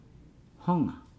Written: honga

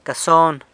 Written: cason

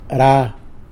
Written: ra

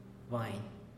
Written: bain

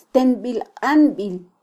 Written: ttenbilqanbil

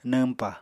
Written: nwmpa

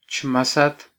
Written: chmasat

